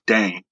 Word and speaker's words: Dane: So I always have to be, you know, polite and stern and be like, hey, it's Dane. It's not Dana. It's not Dante.